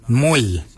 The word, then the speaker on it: mooly: Warrigal is spelled W-O-R-R-A-G-O-L. Warrigal. Warrigal is your upper mouth. For your nose, we say mol. Mol is your nose. Mol is spelled M-O-O-L-Y, Moll. Moll is your nose.